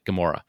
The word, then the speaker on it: Gemora: Some, Gam- it's pronounced like that, Gemora.